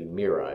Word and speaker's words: Myra: Trying to remember how Father Conway told me it would be Myra is how it would be pronounced. I checked the Greek with him.